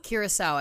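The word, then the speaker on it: Curaçao: Is it Curaco? Curacao, I believe. – It is. So it's like the booze.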